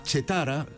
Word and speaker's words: Cetara: E fixadevos no nome, Chetara, que se escribe Cetara.